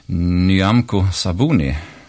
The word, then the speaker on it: Nyamko Sabuni: Nu ska jag se om jag kan säga hennes namn rätt. Nyamko Sabuni, tror jag det ska uttalas.